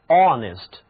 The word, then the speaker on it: honest: honest, 这个字母中的字母 h 是不发音的，直接从字母 o 开始读起，读音就是 honest。